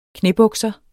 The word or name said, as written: knæbukser